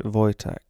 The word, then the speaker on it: Wojtek: It's spelled Wojtek, but you pronounce it Wojtek.